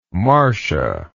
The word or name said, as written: Marsha